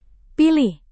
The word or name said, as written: pilih